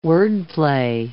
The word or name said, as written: wordplay